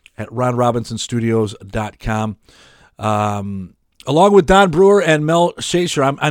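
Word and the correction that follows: Schacher: And it's the and Mel's last name is pronounced Shocker. – Shocker. – Like, you know, yeah, if you stuck your fingers in a light socket, you'd get a shocker.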